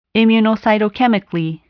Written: immunocytochemically

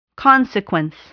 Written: consequence

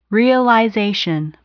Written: realization

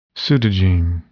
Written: pseudogene

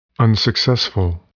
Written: unsuccessful

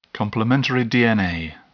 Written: complementary DNA

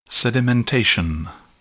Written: sedimentation

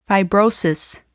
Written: fibrosis

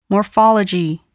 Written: morphology

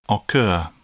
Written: occur

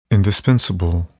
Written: indispensable

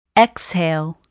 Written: exhale

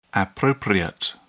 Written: appropriate

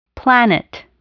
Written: planet

